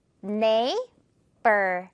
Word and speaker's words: neighbor: 자, 마지막 이 단어, GH 빼버리면요, 발음 쉽죠. Neighbor.